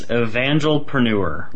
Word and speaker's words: evangelpreneur: Yes, evangelpreneur, like evangelism and then entrepreneurship.